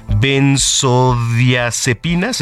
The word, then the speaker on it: benzodiazepinas: Este, me, me tocó pronunciar esto, a ver, déjame pronunciarlo, es benzodiazepinas, ¿está bien? – Es correcto, mi querido Tocayo.